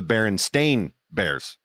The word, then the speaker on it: Berenstain: But it's actually pronounced the Berenstain Bears, apparently, because it's not pronounced it's not spelled S-T-E-I-N. It's spelled S-T-A-I-N.